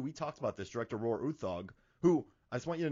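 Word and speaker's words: Roar Uthaug: So I read the Nordic pronunciation. It's like Roar Uthog, but, you know, he pronounces it as Roar Uthog.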